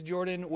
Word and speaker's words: Jorphdan: My name is Jordan with a silent PH in the middle, and welcome to the Saturday Morning D&D Show.